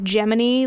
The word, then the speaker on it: Gemini: For whatever reason, they pronounced it Gemini, like Jiminy Cricket.